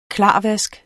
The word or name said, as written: klarvask